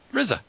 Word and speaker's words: RZA: RZA, RZA. Dingus, how do you say this fellow's name? – RZA, you're right.